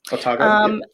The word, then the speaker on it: Otago: Otago, Otago.